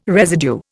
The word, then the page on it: residue: Indian